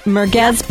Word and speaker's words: Merguez: Merguez Burgers.